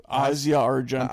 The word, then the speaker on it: Asia Argento: Asia Argento. That's what Google said. So, You know, problematic character because, lies. You know, was she implemented in Bourdain's death? But anyway, a 10-year-old Asia Argento.